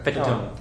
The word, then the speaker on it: Fatone: Fatone. – It's pronounced Fatone.